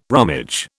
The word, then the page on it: rummage: British English